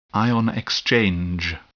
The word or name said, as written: ion exchange